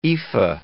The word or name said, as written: ephah